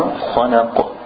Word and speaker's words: خلق: Good, and the qaf, you want, if you vibrate it a little bit more, khalaq.